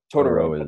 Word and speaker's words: Totoro: Totoro is how you say it.